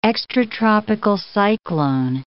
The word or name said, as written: extratropical cyclone